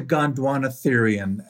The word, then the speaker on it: Gondwanatherian: but you said that it is a Gondwanathan, is that right? It's a Gondwanatherian, and it refers to the fact that these animals lived on Gondwana, and Gondwana was a former supercontinent.